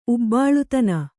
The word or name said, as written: ಉಬ್ಬಾಳುತನ